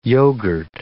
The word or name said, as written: yogurt